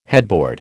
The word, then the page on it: headboard: American English